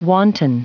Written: wanton

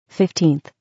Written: 15th

